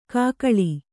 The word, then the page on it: ಕೂಗಳಿ: Kannada